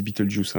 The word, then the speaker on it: Betelgeuse: Hein, parce que les, les, Anglais, ils disent Beetlejuice, hein, euh, littéralement.